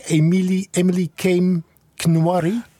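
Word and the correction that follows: Emily Kame Kngwarreye: Emily Kame Nangware.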